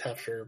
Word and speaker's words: Hefner: He got an invitation to the Hofner. Or from Hofner, I'm sorry. – Hefner. – Yeah. – English is pronounced Hefner, but yeah, it's Hefner.